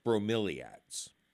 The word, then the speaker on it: bromeliads: And I hate to correct a lady, but it's pronounced bromeliads.